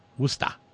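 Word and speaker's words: Worcester: Randy says, having graduated from WPI, I know that the locals pronounce that Wista.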